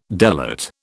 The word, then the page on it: delate: British English